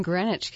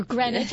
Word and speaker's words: Greenwich: I grew up in Greenwich, Connecticut. – Or Greenwich, I'm sorry. – Greenwich is probably the English pronunciation.